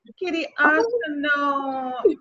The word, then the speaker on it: Kitty Arceneaux: Kitty Arceneaux.